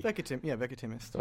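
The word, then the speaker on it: Veckatimest: I've always said Vecatimist. How do you say it? – That works. – Well, what do you say? – Vecatimist.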